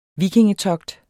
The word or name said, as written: vikingetogt